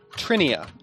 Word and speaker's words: Trinia: Trinia. T-R-I-N-I-A.